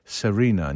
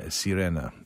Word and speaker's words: Serena: Aczkolwiek no Serena, przepraszam, tutaj słuchacz nam podaje prawidłową wymowę, że mamy czytać Serena, nie Sirena.